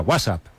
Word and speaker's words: WhatsApp: No, peor aún, con acento en la primera. WhatsApp, ¿no?